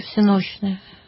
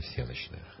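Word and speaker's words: всенощное: По церковно-славянски всеночное. – Всеночное. А мы, как правило, употребляем всеночное.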